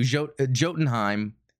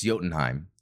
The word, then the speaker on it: Jotunheim: Jotunheim, but it's Jotunheim. The J is silent.